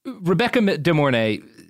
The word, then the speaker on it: Rebecca De Mornay: So, Rebecca De Mornay, am I saying her name right? – As far as I know.